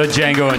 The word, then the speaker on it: Django: The Django Unchained. – You have to say the D is silent.